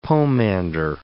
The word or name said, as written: pomander